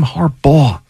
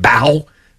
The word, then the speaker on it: Harbaugh: But when people mispronounce these names, like the whole Sam Darnold for so many years, like how do you not know it's Jim Harbaugh? Or John Harbaugh. It's not Bow.